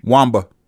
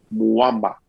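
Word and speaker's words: Mwamba: She said, no, you're not. – Wamba. – She said, you're trying to make a weird sound with the M and the W. Put a U in between that M and the W.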